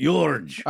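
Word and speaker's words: György Thurzó: In a letter to his wife from Jorge Turzo, hope I said that right. – Jorge. – All right, you say it. Jorge Turzo. – No, just a little Eastern European.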